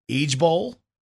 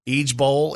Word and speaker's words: IJBOL: And I guess the way they say it is Eej Bowl. Eej Bowl is how they say it. They don't do like LOL where it's all letters and stuff like that.